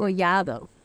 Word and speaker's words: Collado: Collado.